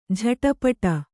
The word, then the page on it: ಜಟಪಟ: jaṭapaṭa